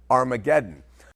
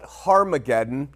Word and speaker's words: Armageddon: Armageddon. But the Hebrews would call it Harmageddon, and the H is silent with the Greek, and that's a mountain.